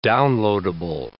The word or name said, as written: downloadable